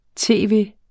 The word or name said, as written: tv.